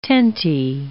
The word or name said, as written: tenty